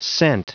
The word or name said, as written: scent